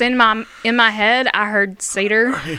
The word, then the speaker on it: Seder: Seder, as it's commonly pronounced mm-hmm. in... English.